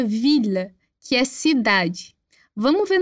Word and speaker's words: ville: No entanto, para as palavras mille, ville e tranquille, a gente vai pronunciar os dois L's com L, normal.